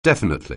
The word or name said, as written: definitely